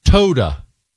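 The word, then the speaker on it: Toda (תודה): Toda. T-O-D-A.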